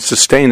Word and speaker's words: Systane: And I often recommend Sustain or Refresh. – And Sustain is spelled with an S. – Yes, S-Y-S-T-A-N-E.